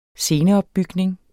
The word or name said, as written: sceneopbygning